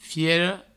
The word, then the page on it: fiera: fiero; Provençal